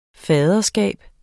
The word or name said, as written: faderskab